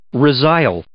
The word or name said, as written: resile